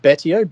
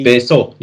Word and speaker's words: Betio: There's a, is it Betio? – Beso, yeah.